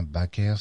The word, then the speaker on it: Baecker: Backer, B-A-C-Q-E-R, donc c'est la version française. Tandis qu'en flamand, eh bien, Backer, ça s'écrit B-A-C-Q-E-R. A-E-C-K-E-R. A-E étant donc en langue flamande, le A prolongé. A prolongé, bien sûr, qui est inconnu en langue française. Donc nous allons retenir, bien sûr, l'orthographe flamande de Baker, B-A-E-C-K-E-R.